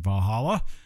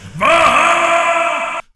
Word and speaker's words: Valhalla: Valhalla. I'm sorry, I didn't say that right. Valhalla! and wake up the next day, group spooning John in a ditch outside of one of the 47 head shops in town.